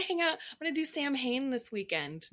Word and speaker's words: Samhain: Well, it's funny because it's spelled like the letters M and H next to each other. I believe in Welsh or Celtic pronunciation creates the W sound. So that's how it's pronounced, Samhain, but it's spelled S-A-M-H-A-I-N.